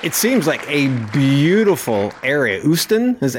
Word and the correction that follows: Ostend: am I saying that right? Usten? – Ostend. Yeah. – Okay. Ostend. Okay.